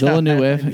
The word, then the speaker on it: Villeneuve: But I think having Denis Villeneuve, Villeneuve, yes. – Isn't that how you say it? – I think so. – Villeneuve. – That's Villeneuve. Yeah.